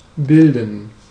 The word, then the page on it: olla: [ˈo̞lːɑˣ]; /ˈol.lɑ(ʔ)/